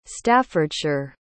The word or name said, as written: staffordshire